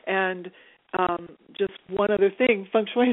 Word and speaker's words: feng shui: Am I pronouncing it right? – I know. Feng shui. Sometimes it's called feng shui, like depending on the dialect.